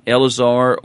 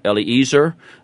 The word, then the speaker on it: Eleazar: And today we're going to once again take a look, and we find in this, whether you pronounce it Eleazar or Eliezer, either way is probably a proper pronunciation. And so I'll probably from now on go with Eliezer.